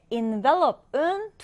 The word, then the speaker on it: envelope: 봉투라고 할 때는 envelope. 그리고 뒤에 envelope은 두 번째 음절을 강하게 읽기 때문에 이때는 사실 앞에 음절은 in으로 발음이 되는 겁니다.